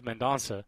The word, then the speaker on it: Mendonca: You do have a guy like Mendonca. I really want to pronounce his name differently, but it is Mendonca, who leads the nation in strikeouts by a wide margin.